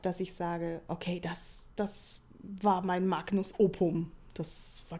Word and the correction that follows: opus: Ähm, Habe ich es wieder falsch ausgesprochen? Wie, wie nennt man es? Magnus? Ich glaube, aber ich habe auch kein Latein gehabt, aber ich glaube Opus und nicht Opum.